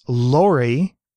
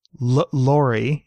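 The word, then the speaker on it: L'LORRI: There's LORI, or LORI, because it's spelled LLORRI.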